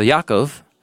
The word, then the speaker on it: Yaakov: And Yaakov, sorry, my translation, I try and spell the names the way they're pronounced in Hebrew. So Yaakov, Jacob, went out from Be'er Sheva, and he went to Haran.